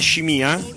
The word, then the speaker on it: Scimia: E tra l'altro la cosa mi riguarda personalmente, perché il mio cognome è Scimia, con una M e l'accento dall'altra parte, ma ho fatto a cazzotti diverse volte nella mia vita perché Scimia.